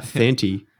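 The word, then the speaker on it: Santi: It's actually pronounced Thanty. You'll get that wrong.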